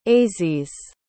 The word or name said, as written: ayzize